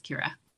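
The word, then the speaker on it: Keira: So mine is Kira. It's spelled K-E-I-R-A-S-M-Y-T-H with an S on the end. A lot of people saw my name wrong, but it's K-E-I-R-A. It's Kira.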